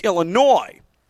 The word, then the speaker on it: Illinois: Yes, the S is there, but you don't pronounce it. It's Illinois.